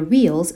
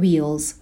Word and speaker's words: wheel: صدای W رو داریم در ابتدا. نمیگم wheel مثل V تلفظ نمی کنم. Wheel هستش. یعنی لبها قنچه میشه. دندونهای بالا به لب پایین برخورد نمی کنه.